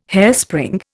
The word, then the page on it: hairspring: Indian